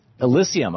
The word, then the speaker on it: Elysium: Elysium. Oh, yeah, as in Elysian Fields.